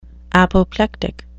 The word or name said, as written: apoplectic